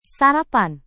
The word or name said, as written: sarapan